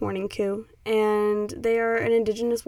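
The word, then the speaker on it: Mourning Coup: So I play Morning Coop on here a lot, and it's spelled M-O-U-R-N-I-N-G space C-O-U-P, Morning Coop.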